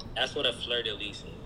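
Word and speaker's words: fleur-de-lis: it's pronounced fleur de lis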